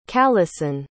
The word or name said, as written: callison